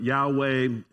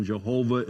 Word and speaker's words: Yahweh: And so it's often translated Jehovah, and that's filling in the vowel sounds. But Yahweh is one pronunciation. Jehovah is another pronunciation.